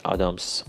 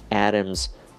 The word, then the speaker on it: Adams: ولی بعد نیست که ما بدونیم این آدامز غلطه و ارمز تلفظ صحیحش هست